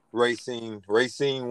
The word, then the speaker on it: Racine: Man, we got a guy, TJ George, sorry about that, from the Beverly Bandits, from Racine, or how you pronounce it? – Racine, Wisconsin.